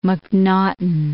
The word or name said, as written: McNaughton